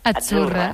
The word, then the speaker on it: Azzurra: Azzurra. Mira, ves, es que Azzurra. Sí, como porque pizza, la Z no es la Z como decimos en catalán tan sonora, no. Ah, es Azzurra.